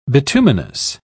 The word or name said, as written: bituminous